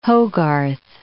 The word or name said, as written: Hogarth